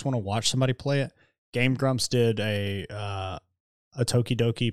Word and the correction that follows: Doki Doki: Or is it Doki Doki? It's Doki Doki Literature. – Doki Doki, yeah. – Yeah. I've been saying it wrong this whole fucking time.